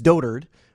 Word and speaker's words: dotard: Kim Jong-un then gave a speech directly to Trump, and then the translation, they used the word dotard. Am I saying that right? – I believe so. – I know it's turd reminds with rhymes with bird because I read about this. So I believe it's dotard, which means old and senile, basically.